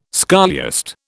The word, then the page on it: scholiast: British English